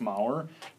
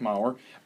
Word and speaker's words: Maurer: My name is Joseph Maurer. My name is Joseph Maurer. Joseph is spelled J-O-S-E-F. Maurer is M-A-U-R-E-R.